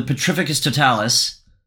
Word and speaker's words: Petrificus Totalis: He hits the alarm and then gets fucking domed with a Petrificus Totalis.